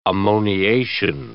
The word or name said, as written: ammoniation